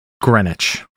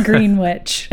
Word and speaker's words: Greenwich: How is this word pronounced? – Greenwich. – Greenwich. – I will never get on board with that. – Not Greenwich.